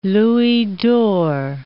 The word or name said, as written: louis d'or